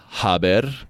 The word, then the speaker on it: haber: So, haber, and that is H-A-B-E-R. Yes, it's not haber because the H is silent in Spanish. So, haber, H-A-B-E-R, and it is also irregular in its conjugations.